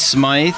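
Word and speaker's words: Smyth: It's Smythe. I saw an interview with her husband, John McEnroe, and he pronounced it Smythe.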